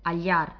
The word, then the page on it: hallar: Spagnolo